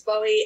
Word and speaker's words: Bohie: So my name's Bowie, and it's spelled B-O-H-I-E, which is a bit different to David Bowie, but all my social media stuff's under that.